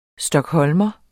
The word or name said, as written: stockholmer